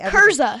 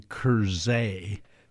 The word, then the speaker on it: Krza: K-R-Z-A. How would you say the name K-R-Z-A? – Kurza. – Kurza. Okay. – Kurza. – Kurza? – Kurza? – Kurza? Kurza? How do we say it? – It's actually Kurzay.